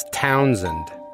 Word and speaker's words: Townsend: Townsend, with the emphasis on town, and the SH turns into a Z, so it's Townsend.